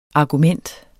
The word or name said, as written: argument